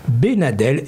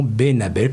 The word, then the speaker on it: Benadel: Il s'agit de la famille Benadel et non Benabel, comme je l'ai prononcé tout à l'heure, et je m'en excuse.